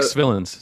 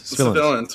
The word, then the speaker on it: Svillens: Like, let's get the guy who played Dino Bravo in our Dino Bravo episode, who is his name is Mike Sylvains. – No, it's Mike Svillens. – Svillens, sorry. I always pronounce his last name wrong.